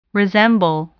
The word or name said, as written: resemble